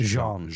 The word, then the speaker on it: Jean: It's not John. It's Jean.